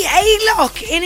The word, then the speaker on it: Alok: No, it says the Alok in English speaking regions and we speak English, so we say Alok.